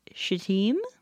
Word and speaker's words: Shittim: They're staying in a place that is probably pronounced Shittim, mm-hmm. but looks like Shittim.